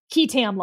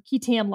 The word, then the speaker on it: qui tam: and they're called KETAM lawsuits. So this is sort of what this is modeled after. And I think it's worth a little bit of a footnote on KETAM lawsuits. So it's spelled QUITAM.